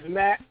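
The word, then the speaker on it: Mak: My name is Baron, Rocker for Jesus Mac. And you can just like Baron Mac McLean on Facebook, and I'm also on Twitter, Baron Mac, and it's spelled B-A-R-O-N-M-A-K. Again, that's B-A-R-O-N-M-A-K.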